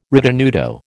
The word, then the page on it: ritenuto: American English